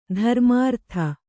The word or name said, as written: ಧರ್ಮಾರ್ಥಿ